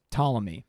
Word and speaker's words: Ptolemy: So Western astrology, like what we use today, is all based on the book that this one guy, Claudius Ptolemy. – Yeah. He's got a P in his name, though. – He does. He has a silent P.